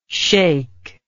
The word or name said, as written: shaikh